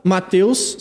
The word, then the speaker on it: Mateus: Verdade, arroba Mateus, sem H, igual da Bíblia, Mateus de pobre, o mais simples possível, uhum.